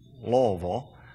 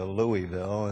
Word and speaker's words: Louisville: And when I first came here, it was Louisville, and then I went to Louisville, and then I started saying it properly, Louisville. And I think that's how you say it.